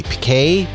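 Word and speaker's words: piqué: The lightweight silver, how do you pronounce this word? P-I-Q-U-E. Pique polo? Pique, yes.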